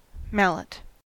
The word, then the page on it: mallet: American English